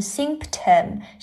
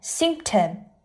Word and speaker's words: symptom: 我们来看两个单词，一个叫做 symptom, 这个单词在读的时候这个 P 不发音，不是念成 symptom, 是念成 symptom, symptom。